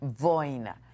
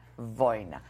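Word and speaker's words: Wojna: Boina, Boina. Es que se escribe de otra manera, Boina.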